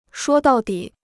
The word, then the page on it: 说到底: shuō dào dǐ